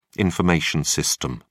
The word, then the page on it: information system: British English